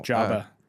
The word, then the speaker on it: Joba: How do you say his name again? – Justin Verlander? – No. – Jabba. – Jabba. – Jabba Chamberlain. – It was Jabba? – Yep. – Yeah. Two O's, but Jabba? – I believe it was J-O-B-A, right? – Yeah.